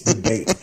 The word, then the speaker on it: DeBate: Was like, hey guys, I think you've been pronouncing his name wrong. It's DeBate.